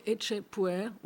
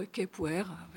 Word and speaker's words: Ecce Puer: Peniche et Ecce Puer » ou « Ecce Puer » selon les prononciations.